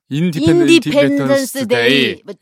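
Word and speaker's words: Independence Day: 제가 그곳에서 처음으로 영화관을 봤었는데 그 영화가 바로 외계인이 나오는 인더펜던스 데이. – 인디펜던스 데이.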